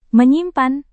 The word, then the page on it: menyimpan: Indonesian